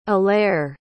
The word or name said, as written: allaire